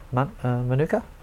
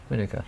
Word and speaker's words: Manuka: Oh, Manuka. – Me too. – Yeah, Manuka. I would say that. – I'd like to know why. I'd like to know what's correct. I've always said Manuka, but I thought you said Monica, and I've heard someone else say that, and I thought...